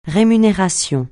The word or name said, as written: rémunération